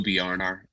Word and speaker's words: Bjarnar: No, it's it's it's Will Bjarnar, Icelandic, uh, is what the surname is.